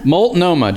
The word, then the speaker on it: Multnomah: Multnomah, just how it's spelled.